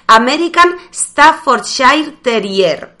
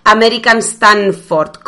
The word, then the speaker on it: American Staffordshire Terrier: cuidado con esto porque los dueños de esta raza son especialmente susceptibles con la manera de pronunciar esta raza y es American Staffordshire Terrier. ¿Por qué son especialmente susceptibles? Porque, bueno, pues la mayoría de la gente llama a este perro American Stanford, con N, Stanford.